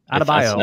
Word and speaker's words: Adebayo: how do you say it? Adebayo? What's the correct pronunciation? – I think you nailed it. – Adebayo. – That's how I say it.